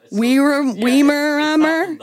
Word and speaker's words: Weimaraner: I'm thinking of a specific breed, but I cannot pronounce the name. Wyramanner? Weimaraner? It's that one.